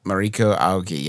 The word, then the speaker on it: Mariko Aoki: Mariko Aoki, yes. Mm-hmm. Yes. – Phenomenon. – Yes. I mispronounced that on the episode. – I just fixed it. That's why I was saying it right.